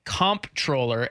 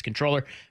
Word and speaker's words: comptroller: But she said the preferred pronunciation is controller, but it's become so common that people pronounce it both ways.